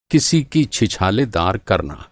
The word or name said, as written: छीछालेदार